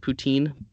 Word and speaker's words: poutine: It depends on your accent. So it's a French word, poutine, but English people can't pronounce French words, so they say poutine, which makes me want to throw up. So I'll let you get away with poutine, but the proper pronunciation is poutine.